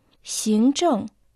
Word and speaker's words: 行政: Синчжэн – административный, администрация. Иероглиф «син» произносится во втором тоне, а иероглиф «чэн» четвертом тоне.